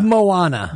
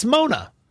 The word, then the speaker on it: Moana: I'm hearing from the back, who actually spoke to the individual, that it's Mona. – Well, then you don't spell Mona M-O-A-N-A. You spell Mona M-O-N-A.